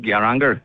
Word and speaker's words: Geiranger: We came this morning from Geiranger. Did I say that right? – Geiranger, yeah.